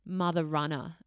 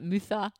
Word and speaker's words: Mutha Runner: And so I started Mother Runner, and it was great name. – And you know, so many people mispronounce it, like Mutha. – Yeah, because it's spelled Mutha.